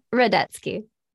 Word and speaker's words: Radetsky: I said that wrong. Can you say your name correctly? – It's Radetsky.